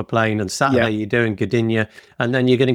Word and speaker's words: Gdynia: What is it? Gdynia or something? – Gdynia. – Oh, out of the blue, I get it right.